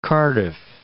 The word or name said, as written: Cardiff